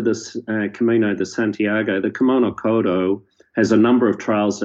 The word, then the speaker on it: Kumano Kodo: So we're looking at the Kumano Kodo Trail. Is that the right way to pronounce it? – Kumano Kodo.